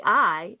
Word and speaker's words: Ai: What happened is God delivered them at Jericho, but when they went to take the next city, Ai, it's pronounced I and it's spelled A-I, they went in to take the city, then they were beaten and ran off, and many were killed.